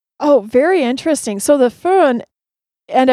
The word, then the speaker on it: Föhn: I had a friend from Switzerland try to teach me how to say Föhn, and I still probably don't say it correctly, do I? – Oh, the first try was perfect. – Oh, wow, Föhn.